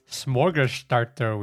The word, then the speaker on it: smorgasbord: And welcome back with episode 3, Smorgasbord Week.